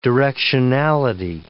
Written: directionality

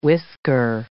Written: whisker

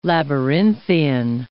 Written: labyrinthian

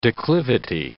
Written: declivity

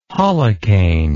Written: holocaine

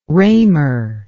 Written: roemer

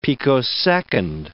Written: picosecond

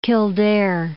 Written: Kildare

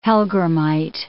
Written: hellgrammite

